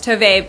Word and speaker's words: Tove: If you guys want to check it out, go to Tove, T-O-V, am I saying it right? – It's Tove, but obviously with the URL, you can't put the accent on the E. – So go to T-O-V-E,